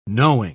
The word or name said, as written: knowing